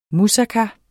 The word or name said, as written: musaka